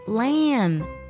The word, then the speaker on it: lamb: 要特别注意 lamb 这个单词虽然结尾是 b，但是 M-B 这个字母组合呢 B 是不发音的，它只发作嗯，所以小羊的发音呢是 lamb。